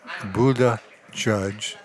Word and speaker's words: Buttigieg: Buttigieg. It looks like Buttigieg, but it's Buttigieg. And I'm formally announcing him.